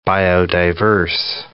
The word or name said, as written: biodiverse